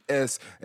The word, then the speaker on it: Corps: It's because it's Corps, and I'm just like, Corps, the P is silent.